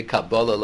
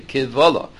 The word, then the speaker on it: Kivvalah: The Shita Mekubetzes there on Bava Kama has a different pronunciation of the word. He doesn't say Dibre Torah Medibre Kabbalah Lo Yafina, not Kabbalah, Dibre Kivvalah, words of complaint.